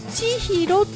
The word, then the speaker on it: 千尋: 私は千尋なんですが、この仲人市の青木さんは千尋と地にアクセントがつきます。